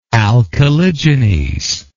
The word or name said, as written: alcaligenes